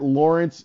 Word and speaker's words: Laurence: At Lawrence W. Holmes. My first name is spelled L-A-U-R-E-N-C-E, not the bastardized American way of spelling it.